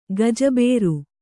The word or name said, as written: ಗಜಬೇರು